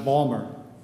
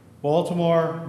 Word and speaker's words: Baltimore: It's kind of like if you go down to Baltimore, the city of Baltimore, the locals pronounce it Balmer. Baltimore, Balmer.